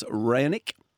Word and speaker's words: Raonic: The Demon, Alex Demonor, he takes on Milos Raonic. – Raonic? – He does. – Raonic? How do you say it? – Raonic. Let's go Raonic.